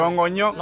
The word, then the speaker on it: Longonyo: Longonyo. – Longonyo. – Yes, that's how you say it.